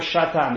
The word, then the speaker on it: Satan: Someone sent me a message wanting to know how to pronounce the word. Is it Satan or Shatan? The answer is it's Satan.